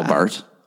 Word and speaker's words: Barth: Karl Barth. – Yeah, exactly. Nice. That's a burn. – Yeah. I don't know if that's how you pronounce his name. Bath? – Yeah, Karl Barth. – Barth? Oh.